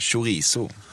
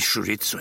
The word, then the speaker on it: chorizo: Den sista korvimporten du orkade lära dig namnet på var chorizo, eller som du skulle uttala det, chorizo. – Nej, chorizo heter det.